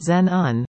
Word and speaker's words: Xenon: The Bragg Creek Snowbird Chalet will come alive with the soulful rhythms of Xenon Plus, R&B peppered with jazz and a whole lot of soul. Xenon Plus, the electrifying duo, pronounced Zen-un, promises an extraordinary vibe.